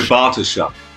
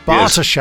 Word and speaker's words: Bartoshuk: In the early 80s, Linda Bars- Bart- Bartoshuk? Bartoshuk? Linda Bartoshuk. – Bartoshuk. Yes. – Oh, nicely pronounced.